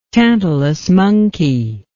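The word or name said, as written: tantamount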